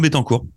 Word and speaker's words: Bettencourt: nous, nous, là, le, le guitariste euh, Bettencourt, comment est-ce qu'on prononce son nom? Ouais, Nuno Bettencourt.